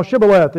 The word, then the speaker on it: Shibboleth: And if he said, "No," they said unto him, "Say now Shibboleth." And he said, "Sibboleth," for he could not frame to pronounce it right.